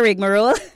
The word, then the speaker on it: rigmarole: Aya rigmarole. So the word for the afternoon is rigmarole. Rigmarole. It is spelled R-I-G-M-A-R-O-L-E. R-I-G, like rig. M-A, like ma. And roll, like R-O-L-E.